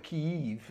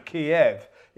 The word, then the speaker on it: Kyiv: Yeah, they say you've got to call it Kyiv because Kiev is the Russian pronunciation.